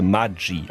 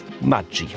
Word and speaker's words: Maggi: Eigentlich müsste das Ganze Maggi ausgesprochen werden, italienisch wie Formaggio. Denn Julius Maggi, Maggi, ist zwar in der Schweiz geboren, aber als Kind eines italienischen Einwanderers. Und tatsächlich heißt Maggi in der Schweiz Maggi.